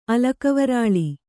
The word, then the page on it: ಅಲಕವರಾಳಿ: Kannada